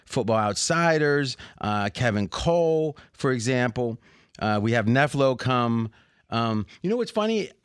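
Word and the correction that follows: nfelo: I heard someone pronounce that. I've been pronouncing it. It's NFL Elo. But for some reason, yeah, it makes more sense.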